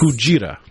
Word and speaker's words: Kujira: Kujira.